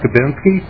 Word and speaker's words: Kubinski: My name is Todd Kubinski.